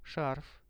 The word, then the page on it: sharf: Russian